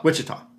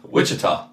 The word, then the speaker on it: Ouachita: Wichita. – Wait, what? – Wichita. – That's how they pronounce it? – Yes.